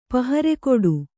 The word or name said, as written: ಪಹರೆಕೊಡು